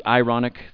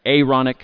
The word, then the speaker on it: Aaronic: It's not the ironic, it's the Aaronic priesthood, as in the priesthood of Aaron.